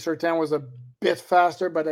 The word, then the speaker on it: Surtain: Ohio John asked, between Sam Madison, Pat Surtain, did I say it right? Surtain? – Surtain.